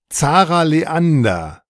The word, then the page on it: Zarah Leander: German